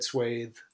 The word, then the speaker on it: swath: What's the American pronunciation? – Swath. – It is swath. – Yeah. – Interesting. Because I've always said swathe.